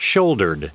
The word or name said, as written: shouldered